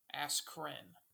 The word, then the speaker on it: Askren: It's like A-S-K-R-E-N, I believe. Askren. Pretty sure. – That was Askern. – I think it's Askern. Dang it, I said it wrong. Askren.